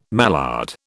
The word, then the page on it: mallard: British English